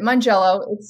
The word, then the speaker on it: Mongiello: Mongiello. It's said easier than it looks.